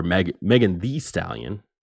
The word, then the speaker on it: Megan Thee Stallion: On Wednesday, the rapper Megan Thee Stallion, make sure you pronounce the Stallion. That extra E is important.